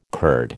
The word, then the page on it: curd: American English